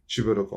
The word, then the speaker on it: çiğ börek: Sokak ağzı öyle ama okunuşu ve yazılışı normalde çiğ börek olması gereken bir ürün.